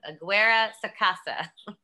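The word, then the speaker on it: Aguirre-Sacasa: Robert Aguesa Sacara. No, I said that wrong. Aguera Sacasa.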